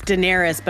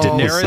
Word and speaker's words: Daenerys: It's Daenerys, but I don't know what the other character's name is.